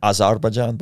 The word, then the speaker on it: Azerbaijan: Azerbaijan, Baku.